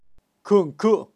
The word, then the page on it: kunku: Kaqchikel